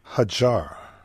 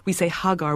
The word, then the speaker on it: Hagar: And in the Judeo-Christian tradition, when we say Hagar with a hard G, and sort of the second wife of Abraham banished other. In the Islamic tradition with a soft G, she's an honored presence, and she actually is the founder of Mecca in her wanderings, as I understand.